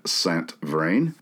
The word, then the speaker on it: Saint Vrain: A family of nobility from Normandy, led by the patriarch Chevalier Jacques Saint Vrain, it's spelled Saint Vrain, V-R-A-I-N, we'll call him Jacques Saint Vrain. He arrived in the French settlement of Saint Louis in the Louisiana Territory sometime shortly after the French Revolution. Chevalier Saint Vrain had been a loyalist and an aristocratic naval captain for the Bourbon dynasty.